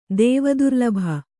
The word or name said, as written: ದೇವದುರ್ಲಭ